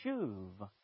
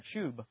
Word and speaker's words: shuv: In Hebrew, the word is shuv. It looks like shub, but it's pronounced shuv.